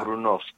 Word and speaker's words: Brunost: Ah, el Brunois. Sí, es un queso terroso, es. – ¿A Brunois? – El Brunois, sí. – Se escribe Brunost. Sí. Yo lo pronuncié tal cual.